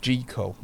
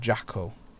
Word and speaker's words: Jekyll: And it is pronounced Jekyll, not Jackal. – I thought it was Jekyll. – It's pronounced Jekyll. – Jekyll. – That's the actual proper pronunciation. Rhymes with treacle. That's how you can remember it.